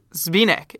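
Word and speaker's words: Zbyněk: but it's Zbynek. It's spelled Z B Y, not Z Y B.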